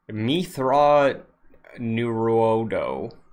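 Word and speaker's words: Mitth'raw'nuruodo: Forgive me if the pronunciation is off, but I brought Mithra Nuruodo. Are you familiar with this? – You killed it. You killed it. 100%. – You are? – Accuracy. – Oh, no. I am familiar with him, but I'm saying that you also killed the pronunciation. 100% accuracy.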